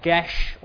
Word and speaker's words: geas: It's called, and I apologise in advance if I mispronounce this, it's called Gesh. It's spelled G-E-A-S, and apparently it's something like Gesh, although it varies depending on where in Ireland you might be from.